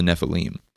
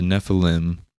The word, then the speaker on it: Nephilim: I think it's fair to say that the Nephilim is technically the Hebrew pronunciation, but people say Nephilim sometimes.